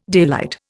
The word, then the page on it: daylight: Indian